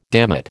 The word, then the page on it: dammit: American English